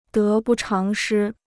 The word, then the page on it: 得不偿失: dé bù cháng shī